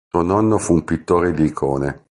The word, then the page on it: nonno: italian